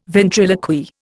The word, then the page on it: ventriloquy: Indian